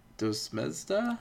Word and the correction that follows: Desdemona: Oh, uh, mm-hmm. Desdemona. Desdemona, thank you.